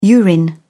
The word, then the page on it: urine: British English